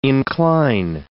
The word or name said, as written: incline